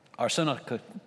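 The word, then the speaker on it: arsenokoitai: The other term, which has to do with homosexuals here, arsenokatai, it's a long one, it's a four-syllable Greek word, does not mean homosexual.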